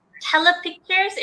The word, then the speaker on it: Telepictures: And I know that as you were, like, you, I think after that, you took a role at Telepictures, if I'm correct with pronouncing the name.